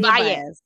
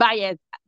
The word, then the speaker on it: Baez: Baez. Baez. Yep. Baez. Because I was like, wait, how do you say her last name? So B-A-E-Z? – That's right, girl.